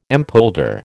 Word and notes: empolder: American English

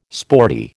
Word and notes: sporty: American English

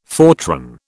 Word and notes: Fortran: British English

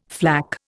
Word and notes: flak: Indian

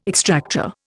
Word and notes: extractor: Indian

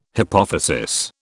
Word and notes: hypophysis: British English

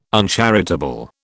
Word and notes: uncharitable: British English

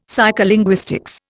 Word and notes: psycholinguistics: Indian

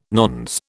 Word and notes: nones: British English

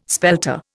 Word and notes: spelter: Indian

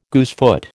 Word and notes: goosefoot: American English

